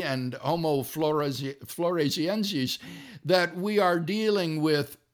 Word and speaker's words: floresiensis: In addition, the origins of Homo, and how do you say that? Because they're Floresiensis.